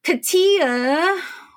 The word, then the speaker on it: Katya: Katia, which this one, it's one of those names that I did have to look up. I'm not even going to lie. It's spelled K-A-T-Y-A, but it's pronounced Katia.